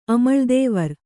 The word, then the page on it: ಅಮಳ್ದೇವರ್: amaḷdēvar